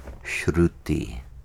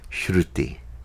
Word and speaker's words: Shruti: Shruti is spelled S-H-R-U-T-I. And there's a little dot under the R indicating that it has a one-tap roll. One tap means Shruti. R, one tap. The tongue taps once. R. It's not a multiple tap like R. It's not Shruti.